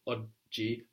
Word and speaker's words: oggi: That's O-G-G-I. Oggi. – Oggi. – So you're seeing these double consonants are a big deal in Italian. They're a big feature of Italian. We must get used to stopping on them, no? Taking our time to pronounce both oggi, which feels and sounds like a pause, no?